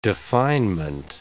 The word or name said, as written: definement